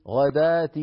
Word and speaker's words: الغداة: غدات، والواو هنا ما هي إلا كرسي للألف تماما كالصلاة والزكاة والنجاة والحياة، هذه الكلمات كتبت في المصحف الشريف بواو ولكنها تنطق بألف.